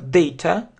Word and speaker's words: data: Потому что многие просят как data, но это неправильно. Потому что, видите, если просто транскрипцию, значит, у нас апостроф перед ударным слогом, значит, ударение на это day. Data. Data. Это как э, слабое э. Data, data, data, data. То есть это не а, а слабое э. То есть, опять же, не зная транскрипцию, об этом трудно догадаться. Это ошибка в произношении.